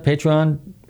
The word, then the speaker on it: Patreon: Patreon, how do you pronounce that? Patreon. Yes.